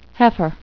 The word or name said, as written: heifer